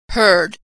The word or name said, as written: heard